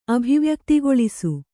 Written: ಅಭಿವ್ಯಕ್ತಿಗೊಳಿಸು

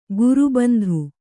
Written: ಗುರುಬಂಧು

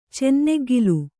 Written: ಚೆನ್ನೆಗ್ಗಿಲು

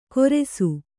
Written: ಗೊರೆಸು

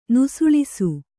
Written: ನುಸುಳಿಸು

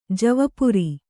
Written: ಜವಪುರಿ

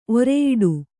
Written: ಒರೆಯಿಡು

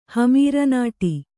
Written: ಹಮೀರನಾಟಿ

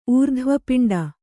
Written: ಊರ್ಧ್ವಪಿಂಡ

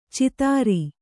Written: ಚಿತ್ತಾರಿ

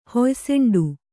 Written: ಹೊಯ್ಸೆಂಡು

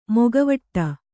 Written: ಮೊಕವಾಡ

